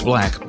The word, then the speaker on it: 6LACK: It's pronounced Black, but it's spelled the number 6, L-A-C-K.